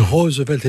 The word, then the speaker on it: Roosevelt: Parce que à partir de Nicolas, des enfants de Nicolas plus exactement, la famille Roosevelt, vous l'avez très bien prononcé, ça se dit Roosevelt et non pas Roosevelt comme on est tenté de le faire dans un pays francophone, c'est le moment où la famille prend sa singularité historique.